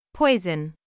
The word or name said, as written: poison